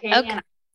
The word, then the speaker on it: Acadiana: Am I saying that right? – Yeah, you got it right, Acadiana.